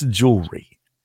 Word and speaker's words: jewelry: It's jewelry, not jewelry. And she says, what did I say? And I said, is it jewelry? – Jewelry. – Which is why I stopped. Three syllables you. – Instead of two. Yeah.